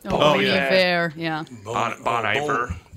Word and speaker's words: Bon Iver: Now they're known for the guy, I can never say his name right, Bon Iver. – Bon Iver.